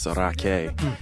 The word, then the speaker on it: Rakei: So the last name is spelled R-A-K-E-I, but it's actually Maori, so it's pronounced Raque.